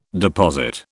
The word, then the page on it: deposit: British English